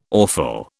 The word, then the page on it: awful: British English